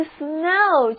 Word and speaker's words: snow: 这个 snow 就是雪或者下雪的意思了。 Snow, S-N-O-W，字母组合 O-W 读作 O。